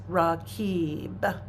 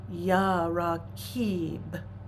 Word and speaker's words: Raqib: So the practice is Raqib. It's spelled it transliterates R-A-Q-I-B, but the Kib is the emphasis. Ya Raqib.